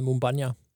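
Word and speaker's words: Moumbagna: Så har vi fået Faris Mumbanja til Sønderjyske fra Christiansund i Norge. – Jeg tror, han hedder Mumbanja. – Mumbanja, okay. Der er du lidt skarpere på udtalen, så.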